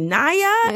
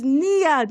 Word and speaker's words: Niyah: and Nia is N-I-Y-A-H. So thank you so much for saying it right, because people say Naya, and I was like, no, it's Nia.